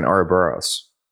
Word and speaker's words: Ouroboros: I thought you got like an Ouroboros. Is that how you pronounce that? The snake eating its own tail? – Oh, yeah.